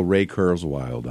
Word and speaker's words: Ray Kurzweil: Let me go Ray Kurzweil on you.